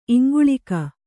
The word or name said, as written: ಇಂಗುಳೀಕ